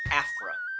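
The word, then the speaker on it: Aphra: Afra. Okay. Like Especially Afro, except with an A.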